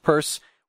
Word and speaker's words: Peirce: We all know it's spelled Pierce, it's pronounced Purse.